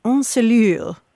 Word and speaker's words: ensellure: It's the term encelure. – Say it again. – It's spelled E-N-S-E-L-L-U-R-E, encelure. Oh.